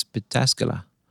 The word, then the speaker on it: Pataskala: It's Pataskala.